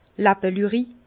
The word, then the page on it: Làppelüri: Bas Rhin; Herrlisheim